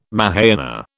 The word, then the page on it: Mahayana: British English